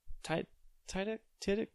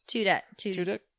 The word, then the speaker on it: Tudyk: But funny enough, like, it has James Tiddick or whatever, the Wash, whatever, from. Firefly. How do you say his Alan, name? Alan. Tudyk.